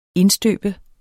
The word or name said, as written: indstøbe